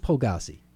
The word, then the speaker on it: Pulgasari: Pulgrassi, I'm not going to say that right, Pulgas Air? Okay, P-U-L-G-A-S-A-R-I. They're going to do an acoustic release show. – Pulgassi? – With Say again? – Pulgassi? – Yeah, we'll go with Pulgassi.